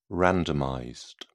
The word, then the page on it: randomized: British English